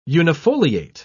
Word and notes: unifoliate: British English

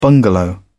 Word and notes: bungalow: British English

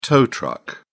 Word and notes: tow truck: British English

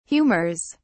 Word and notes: humors: British English

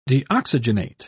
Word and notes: deoxygenate: British English

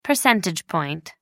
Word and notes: percentage point: American English

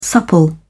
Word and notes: supple: British English

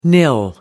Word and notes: nil: American English

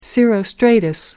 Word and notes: cirrostratus: American English

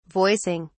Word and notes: voicing: British English

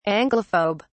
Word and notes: anglophobe: British English

